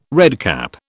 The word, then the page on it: redcap: British English